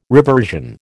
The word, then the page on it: reversion: American English